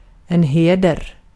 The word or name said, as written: heder